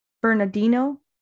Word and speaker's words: Bernardino: Like I was reading that the uh, CSU San Bernard- Bernardino, am I saying it wrong? Bernardino.